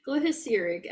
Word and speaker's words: glyceric: And she says the acid in the licorice, which is some kind of glitty ceric, Cerazic acid, I can't pronounce it. – That sounds right licorice. To me. – Yeah, glyceric acid in licorice, when it dissolves, it conducts electricity, and she can use it to call Discovery.